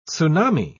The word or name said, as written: tsunami